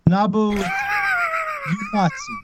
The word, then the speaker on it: Uematsu: Naboo Uematsu.